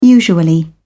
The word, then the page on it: usually: British English